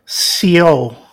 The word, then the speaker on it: Sioux: So, yeah, based on the Sioux natives, S-I-U-X. I don't know if you know this or not. You don't really pronounce the X. It's like Sioux.